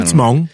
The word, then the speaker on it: Hmong: And the place next to it was a Hmong funeral home. And I'm not sure if I'm even saying that right. I don't know. – Hmong.